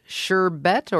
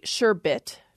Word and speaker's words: sherbet: So both of these pronunciations seem to be standard according to most dictionaries. It's originally sherbet, no R, comes from Turkish, actually goes back to Arabic.